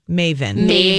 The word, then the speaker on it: Meyvn: When you see the sign, it's pronounced Maven. – Maven. – M-E-Y-V-N. Yes.